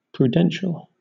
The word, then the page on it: prudential: British English